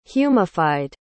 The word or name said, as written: humified